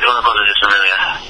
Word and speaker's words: Una Cosa Di Familia: Una Cosa Di Familia, is that close? – That's pretty good. Una Cosa Di Familia, yeah.